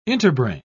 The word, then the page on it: interbrain: British English